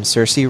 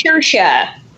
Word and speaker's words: Saoirse: That said, so this movie stars, and I've always pronounced her name Saoirse Ronan. I feel like I'm saying that wrong. Is that true? – It's Saoirse.